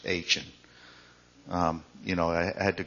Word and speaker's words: Achan: And then there was one that said, this is how you pronounce it, Achan. So, anyway, that's how you say it.